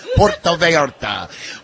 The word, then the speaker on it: Puerto Vallarta: Yeah, we're live at Shooters tonight for the big giveaway, Cabin Fever Getaway 2027, Rio Pacifico, Puerto Vallarta, Mexico. That's how you say it, Corso. Puerto Vallarta.